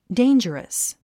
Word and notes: dangerous: déindʒ ə rəs; デェンジュラス

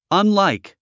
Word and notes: unlike: ʌnláik; アンライク

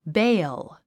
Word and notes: bale: béil; ベィヲ

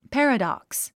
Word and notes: paradox: pǽrədὰks

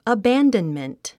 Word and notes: abandonment: əbǽndənmənt; アバァンドンメント

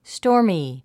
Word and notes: stormy: stɔ’ː r mi; ストーミィ

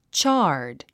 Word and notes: charred: tʃάː r d; チャード

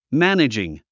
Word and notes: managing: mǽnədʒɪŋ; マネジン

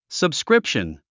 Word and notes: subscription: səbskrípʃən; サブスクリプション

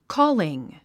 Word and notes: calling: kɔ́ːliŋ; コーリン

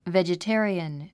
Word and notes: vegetarian: vèdʒəté ə riən; ベジタリアン

